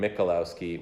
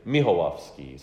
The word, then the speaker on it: Michalowski: And as you so nicely pronounce my name, Michalowski, when I'm in Poland, they say Michałowski. So in Poland, it's Tedziu Michałowski.